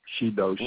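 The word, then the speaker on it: shidoshi: And that was phonetically, not in kanji, but phonetically, shi-do-shi.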